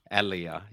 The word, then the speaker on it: Ellia: And just so everybody knows, my name is really hard to pronounce. It's Ellia. – Ellia, yeah.